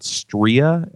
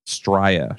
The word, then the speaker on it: Stria: Stria, S-T-R-I-A. – Stria, sure.